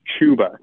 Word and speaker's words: Chuba: And it's pronounced Chuba, guys. Come on.